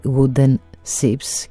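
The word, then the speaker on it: Wooden Shjips: Βέβαια πριν από το I υπάρχει και ένα J, αλλά προφέρεται Wooden Ships και γράφεται SHJIPS.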